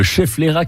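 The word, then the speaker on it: schefflera: Bon, au moins s'il pleut, on est à l'abri avec le chefflera. – Vous l'avez bien prononcé, le chefflera qui est une plante d'intérieur et on l'appelle la plante parapluie.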